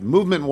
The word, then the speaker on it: MVMT: Movement watches, and you'll see that it's pronounced Movement, but it's actually MVMT watches.